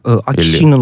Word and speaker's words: Акисино: Кто такой Акисино? Я правильно вообще произношу? Или Акисино? – Акисино но Мия, но родные и близкие его зовут Фумихито.